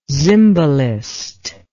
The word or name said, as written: Zimbalist